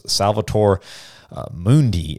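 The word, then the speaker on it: Salvator Mundi: Uh, the most expensive painting ever sold was Salvator uh, Mundi, is how you say it, by Leonardo da Vinci.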